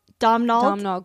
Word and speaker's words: Domhnall: So basically it's Dominic Gleason. Is that the redhead guy's name? – Domhnall Gleason. – His name is Domhnall. – It's something like Donald Gleason. Domhnall. It's spelled Domnall. – Okay, so maybe it is Domhnall. – It's an Irish thing.